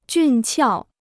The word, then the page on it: 俊俏: jùn qiào